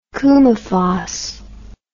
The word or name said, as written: coumaphos